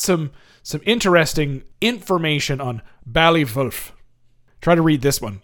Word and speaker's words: Wulff: W-U-L-F-F. Wolf. I think that's how you say it in with the German accent. – Yeah, that sounds German to me.